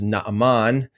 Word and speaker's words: Naaman: We're going to be talking about Naaman, okay? More accurately pronounced as Naaman.